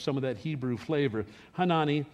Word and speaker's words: Hanani: That's how it's pronounced. It's not Hanani. It's Hanani in the Hebrew.